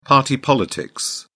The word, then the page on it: party politics: British English